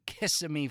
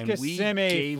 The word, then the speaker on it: Kissimmee: Yes, we were the home team in Kissimmee, Florida. I'm sure I'm pronouncing those correctly. – No, it's Kissimmee, Florida, you turdbird.